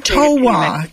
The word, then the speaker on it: Towa: Just a correction on your pronunciation. I'm a descendant of the Towa people. – Towa. Thank you.